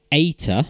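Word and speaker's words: Aita (艾特): The at symbol, pronounced Aita, A-I-T-A, in Chinese, which sounds similar to the phrase meaning love him, one couple felt the symbol was a fitting name for their son.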